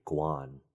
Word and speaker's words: Kwan: Because it's actually Guan. – Oh. – Yeah. – G-U-A-N, but in it's romanized a K-W-A-N.